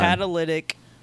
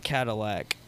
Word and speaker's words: catalytic: Catalytic converter. – Catalytic. – Not Cadillac converter. – But it's easier to say Cadillac. – But it's a catalytic.